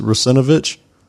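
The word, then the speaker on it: Russinovich: I think you said it's very close. It's Rusinovich.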